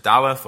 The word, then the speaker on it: Daleth: This fourth section, the fourth letter of the Hebrew alphabet, we can either pronounce it Daleth or Dalet, and it signifies in its pictorial sense one of two things.